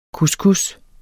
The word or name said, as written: couscous